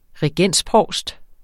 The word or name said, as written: regensprovst